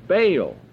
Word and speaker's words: Baal: If Baal, B-A-A-L, pronounced in Hebrew, Baal.